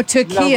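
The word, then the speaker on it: Türkiye: Or Turkey, as we now say. – I'm just going to say Turkey. I'm not saying Turkey. – Oh, okay. It's too hard for you to pronounce the country as they'd like to be called.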